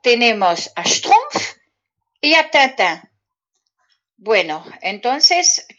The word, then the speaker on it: schtroumpf: Y pitufos, no se dice pitufos en francés, se dice schtroumpf. Mira qué cómico. Pitufo y en francés se dice schtroumpf.